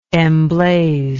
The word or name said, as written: emblazer